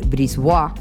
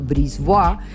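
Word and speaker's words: Brisebois: Daniel Brisbois, así se pronuncia, Daniel Brisbois.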